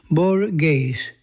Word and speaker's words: Borges: Anglophones tend to pronounce the Spanish language author's name Borges, and that is acceptable, after all attempts at a more authentic pronunciation involving the voiceless velar fricative that attests to the long march of the vocalization.